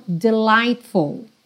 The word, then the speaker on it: delightful: Esse GH aí do meio, você pode esquecer, tá? Não tem pronúncia, então delightful. E voltando para o começo dessa frase, but com the, você já junta num som só.